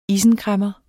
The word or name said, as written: isenkræmmer